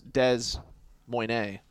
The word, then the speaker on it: Des Moines: But the stewardess on the plane literally asked, because she did not know how to pronounce it, if it was Des Moines. I think that's what they said today. Des Moines. And Paulie and Dan said, yep, that's it.